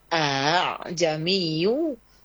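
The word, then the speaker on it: a'jamiyyu (أَأَعْجَمِيٌّ): Seperti kalimat a'jamiyyu wa 'arabi, dibacanya antara hamzah dengan alif, jadi bukan aa tapi a'jamiyyu wa 'arabi.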